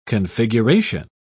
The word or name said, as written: configuration